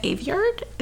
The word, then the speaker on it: Aveyard: For fantasy, I have Victoria Aveyard. Is that how you say her name? – Yeah.